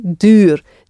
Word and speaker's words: duur: The word for dear, expensive, is duur. U, so I get that U sound again, like in nu. Okay, duur. D-U-U-R.